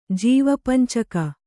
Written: ಜೀವಪಂಚಕ

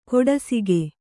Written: ಕೊಡಸಿಗೆ

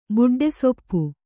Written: ಬುಡ್ಡೆಸೊಪ್ಪು

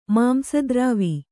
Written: ಮಾಂಸದ್ರಾವಿ

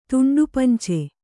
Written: ತುಂಡುಪಂಚೆ

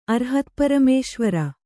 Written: ಅರ್ಹತ್ಪರಮೇಶ್ವರ

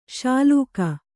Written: ಶ್ಲಾಘಿ

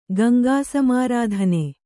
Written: ಗಂಗಾಸಮಾರಾಧನೆ

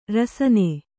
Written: ರಾಸನಾ

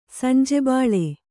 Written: ಸಂಜೆಬಾಳೆ

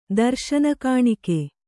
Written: ದರುಶನಗಾಣಿಕೆ

